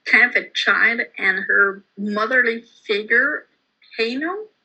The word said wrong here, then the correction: Aino: am I pronouncing that right? – I call her Aino. – Aino, okay, mm-hmm.